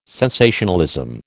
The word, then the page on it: sensationalism: American English